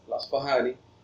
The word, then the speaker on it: الأصفهاني: الأصفهاني يعني لا تنطق فاء خالصة عربية ولا تنطق باء عربية خالصة، وإنما مثل الفي عند رطانة العجم، تمام؟